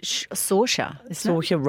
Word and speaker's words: Saoirse: Saoirse Ronan, how do I pronounce it? – Saoirse Ronan.